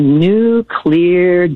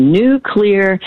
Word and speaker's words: nuclear: it's cloudy today, and maybe, but tomorrow it's going to be a new clear day. Just say new clear, and you will pronounce nuclear correctly.